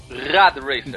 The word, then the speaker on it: Rad Racer: Olha, e, e, só que ele foi baseado num outro jogo de NES chamado Red Racer, pronunciei certo? – Rad Racer. Exatamente. Não, Rad Racer, Rad Racer.